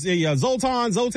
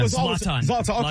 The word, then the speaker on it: Zlatan: I have zero complaints from commentators to Lexia Lawless and Zolt was it, how you pronounce the guy's name? I always mess his name up. Ibrahim Marimovic. Help me out. Zoltan. – Yeah, I used to call him Zoltan. – Zlatan. Okay. Zlatan.